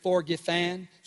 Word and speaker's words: forgifan: I'm going to share more about this in coming weeks, but do you know that the English Anglo-Saxon word, if you will, for forgiven is pronounced actually forgifan?